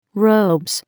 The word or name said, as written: robes